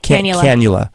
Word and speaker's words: cannula: I'm not even sure how it's pronounced, like a candela? – Cannula. – Not cannelloni, cannula.